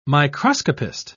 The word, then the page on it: microscopist: British English